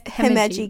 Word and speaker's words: Himeji: So I chose that oh, I'm gonna screw up how to say it, but it's H- Himeji.